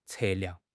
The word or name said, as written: 测量